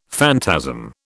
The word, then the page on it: phantasm: British English